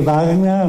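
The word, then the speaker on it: bagna: O sea, nosotros, más que baña, voy a hacer una corrección, baña. Vamos a usar baña porque gene en italiano es pronunciaigne.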